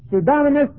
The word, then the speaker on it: pseudonymous: Pseudonymous means false name, and it's spelled this way. P-S-E-U-D-O, and you put the emphasis mark on that O, N-Y-M-O-U-S, pseudonymous, or pseudonymous.